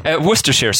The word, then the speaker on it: Worcestershire: So in this episode, Worcestershire sauce, and yes, that's how it's pronounced, approximately. I grew up about 2 hours from Worcester, so although locals in the city may disagree slightly with my accent, I'm not too far off.